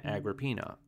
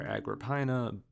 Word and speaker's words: Agrippina: She actually would have been born Vipsania Agrippina, or Agrippina. Both pronunciations are valid, but I'll be using Agrippina in this show.